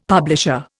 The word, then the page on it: publisher: Indian